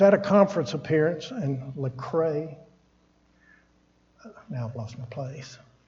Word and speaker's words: Lecrae: His name is Lecrae, an American Christian rapper, songwriter, record producer, and actor.